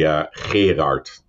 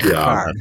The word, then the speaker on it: Gerard: I'll say it once the way the Dutch pronounce it. – Okay. – It's actually Gerard. – Oh my God. – No. Gerard.